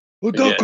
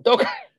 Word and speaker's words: Udoka: Udoka? – Yeah. – How do you say it? – Udoka. – Yeah. Udoka.